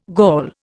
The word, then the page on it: Gaul: Indian